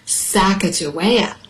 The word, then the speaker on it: Sacagawea: She has a fancy name, Sacagawea. You say it like this, Sacagawea, Sacagawea.